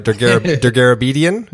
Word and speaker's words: Dergarabedian: Now, make sure I'm saying this right. Degarabedian, is that right? – Perfect.